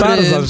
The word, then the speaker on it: Tarzan: Tarzan, że niby RZ, ale nie czyta się jako Ży, tylko Rzy.